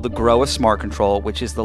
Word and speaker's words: Grohe: There is, and the company that is behind it is called Groa. It's spelled G-R-O-H-E, but pronounced kind of like you grow a flower.